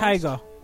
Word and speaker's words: taiga: But anyways, leading into this week's podcast, Douglas wanted us to watch this film by the name of Happy People, A Year in Taggia. Is that how it's pronounced? – Taiga.